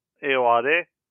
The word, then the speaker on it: Ayoade: Tonight we'll be discussing The Double by Richard Ayoade.